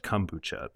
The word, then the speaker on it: kombucha: out in L.A., and it says, quote, "It's definitely pronounced kombucha because it comes from kombu, Japanese for seaweed, combined with ocha, which is the Japanese word for tea." Hmm. So you get kombu plus ocha, kombucha.